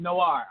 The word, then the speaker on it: noir: Noir. Okay. – Noir. – There we go. Noir. I always get confused on that. – It's French, man. It's noir. – Right. Yeah. – Noir.